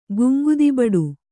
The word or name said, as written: ಗುಂಗುದಿಬಡು